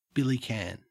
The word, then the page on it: billycan: Australian English